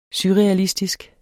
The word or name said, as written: surrealistisk